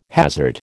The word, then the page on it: hazard: American English